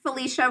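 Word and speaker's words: Falisha: So it's at Felicia Rexford. But my name is spelled really funny. So it's F-A-L-I-S-H-A.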